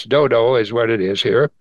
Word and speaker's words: Dodo: You can say Dudu if you want, but it's actually pronounced Dodo.